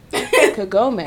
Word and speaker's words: Kagome: Anyone pronounce it Kagame. That's interesting. Sorry. Continue. – I mean, that's how it would be written. Like, Kagome. – That's how it... Yeah. – Yeah. – How... Wait. How yeah, you heard it pronounced? – Kagome. – Oh, Kagome. Yeah.